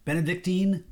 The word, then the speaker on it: Benedictine: The Benedictine. Is that am I saying that right? – Benedictine.